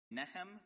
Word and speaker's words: Nahom: Nehem, because that's how I understand locals tend to pronounce the name in Yemen.